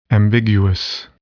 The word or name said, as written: ambiguous